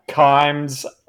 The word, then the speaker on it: Kimes: Kimes rhymes with climbs."